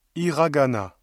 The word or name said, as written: hiragana